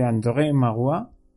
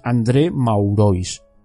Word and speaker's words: André Maurois: Os puedo asegurar que he puesto el Google Traductor francés para escuchar la vocecilla esa que lo lee, para saber cómo se pronunciaba. Si lo, lo leéis, pon, así pronunciado en castellano es André Maurois, un famoso escritor francés.